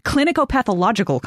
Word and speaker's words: clinicopathological: What Judean king died in 4 BC of kidney disease and Fournier's gangrene, according to a 2001 clinicopathical conference? Clinicopathological conference. Missed a couple syllables there.